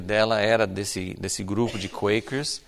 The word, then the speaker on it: Quakers: não é a pronúncia que normalmente as pessoas dão, né? Mas né, em inglês é Quakers.